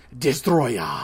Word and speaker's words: Destoroyah: This is Godzilla versus Destoroyah, because you have to pronounce it right. – Destoroyah. – You can't just say Destoroyah, because that just sounds lame. It's you don't want to say his English name, which is just Destroyer. So it's Destoroyah. You gotta accent it a lot. You gotta Japanese it a lot.